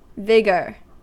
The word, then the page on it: vigour: American English